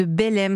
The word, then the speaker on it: Beleyme: Vous êtes la fondatrice de Belém. Ça s'écrit B-E-L-E-Y-M-E.